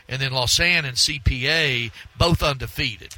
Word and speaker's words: Lausanne: Yeah, Lausanne.